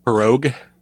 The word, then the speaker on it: pirogue: It's pronounced pirogue. – Pirogue? – Yes. – You know, I pronounced it four different ways, and none of them were that. – Yeah, no, it's pirogue.